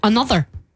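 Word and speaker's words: ANOTR: And for ages, I was pronouncing them as Antor. But apparently... – No, it wouldn't be Antor, because it's A-N-O-T-R. – But no, it's apparently pronounced another.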